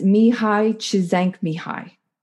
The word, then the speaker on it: Csikszentmihalyi: The book is called Flow, and it's Mihai Csikszentmihalyi.